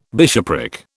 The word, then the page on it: bishopric: British English